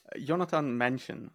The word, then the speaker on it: Jonatan Männchen: Jonatan Männchen, so it's a German thing, but yeah, pretty close.